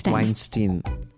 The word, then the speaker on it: Weinstein: Weinstein.